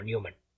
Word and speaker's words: Neuman: Yo juraría que esto es Neumann, no Newman, porque de hecho se escribe con U, no con W. Pero no sé cómo se pronuncia. – Yo en una entrevista vi a William Mills pronunciarlo como Newman.